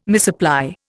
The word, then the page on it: misapply: Indian